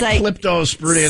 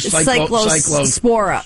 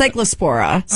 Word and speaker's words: Cyclospora: It's not Cyclosporidium. It's like Cyclospora. Spora. It's Cyclospora. So okay, it's the the new dieting trend right now, Cyclospora skinny.